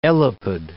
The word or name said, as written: elapid